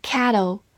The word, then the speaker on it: cattle: 它是一个名词，表示湖或者水湖，而我们今天学习的 cattle 是大口的 a，cattle，注意发音上的细微差异哦。